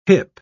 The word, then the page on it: hyp: British English